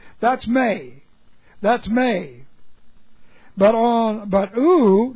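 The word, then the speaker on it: may (μή): That's M-E. May, pronounced may. It's a long E.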